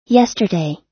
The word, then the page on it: yesterday: American English